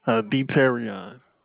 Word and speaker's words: Deterion: Deterion. – Can you spell that for me? – All right. That's D-E-T-E-R-I-O-N.